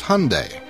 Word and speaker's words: Hyundai: It's Hyundai, like Sunday. – Yeah, my apologies to Hyundai and all other owners of a Hyundai. Rhymes with Sunday. – How did you say it? Hyundai? – Yeah, I think so.